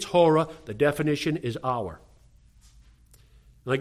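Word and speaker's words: hora (ὥρα): And hora, if you spell it in English, is H-O-R-A. And it sounds like, yep, hour.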